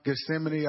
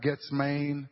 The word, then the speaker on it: Gethsemane: I remember one time, Gethsemane, I pronounced Getsmane.